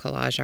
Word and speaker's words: Kolaj: So I went to some shows of different collage artists. There's magazines that are specific to collage art out there. There's one that's called Collage, but it's spelled K-O-L-A-J.